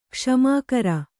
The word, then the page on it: ಕುಸುಮಾಕರ: kannada